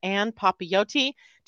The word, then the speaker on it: Ann Papayoti: I would love to introduce you to Anne Papayoti. Did I just say that right, Anne? – You did. Very good.